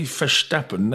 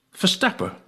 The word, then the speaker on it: Verstappen: By the way, in the Netherlands, they don't pronounce the N at the name on any names. Like, I live near a town called Vlissingen, as you would say, but they say Vlissingen, and they don't say Verstappen, they say Verstappen.